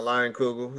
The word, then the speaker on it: Leinenkugel: I was saying it wrong though. I thought it was called Leinenkugel. – Nah, Leinenkugel.